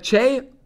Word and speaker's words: Choi: The last name Cha, it is spelled C-H-O-I. You can either go with Che which sometimes I try to, but my Wisconsin accent sometimes comes in. And then you can go Choi, obviously, as well.